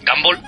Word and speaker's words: Gumball: Bueno, entonces nos ha quedado una cosa clara que hay que ver el mundo de Gumball, ¿lo he dicho bien? – Sí, el asombroso mundo de Gumball aquí en España.